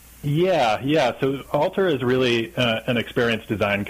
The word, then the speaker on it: ALTR: I'm not sure exactly how you pronounce it. I guess it's spelled A-L-T-R, correct? – Yeah, Alter.